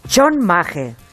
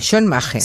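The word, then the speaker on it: chonmage: Se ha hablado tanto de este de este moñito de samurái, que, en verdad el origen, el, término correcto es shonmage. He tenido que escuchar cómo se pronuncia en japonés. – Chon Shonmage. Shon Maje. – Sí, como dos palabras juntas.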